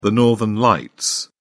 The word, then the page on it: northern lights: British English